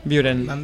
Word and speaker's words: Buuren: Buuren. – Muy bien, esa pronunciación, ¿eh? – Es y que somos belgas también.